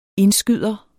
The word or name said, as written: indskyder